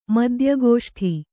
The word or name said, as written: ಮದ್ಯಗೋಷ್ಠಿ